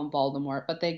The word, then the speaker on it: Voldemort: Did you know that also technically his name is French, so it's not actually supposed to be pronounced Voldemort? – I know, but I hate leaving off the T. – It's supposed to be Voldemort.